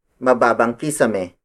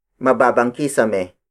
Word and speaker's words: mababang kisame: As a phrase, we can use it like mababang kisame. Mababang kisame. Mababang kisame. Mababang kisame is spelled as M-A-B-A-B. A-N-G, space, K-I-S-A-M-E.